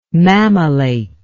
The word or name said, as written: mammillate